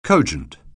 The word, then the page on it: cogent: anglais